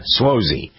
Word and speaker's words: Suozzi: Yeah, Suozzi.